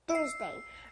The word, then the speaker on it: Thursday: Thursday. – Very good. 목요일, Thursday. 앞에 Th 소리 들렸나요?